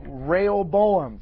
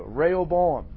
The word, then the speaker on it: Rehoboam: Item 2A, it's actually the story of, how do you pronounce that? – Rehoboam. – Thank you. Rehoboam.